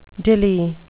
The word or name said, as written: dilli